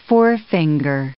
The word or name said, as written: forefinger